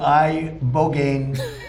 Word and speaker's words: ibogaine: It's called ibogaine treatment. Ibogaine. Remember the name. Is that pronounced relatively properly, would you say? – Yes.